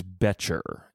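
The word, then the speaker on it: Boettcher: It is Betcher, Emma Betcher.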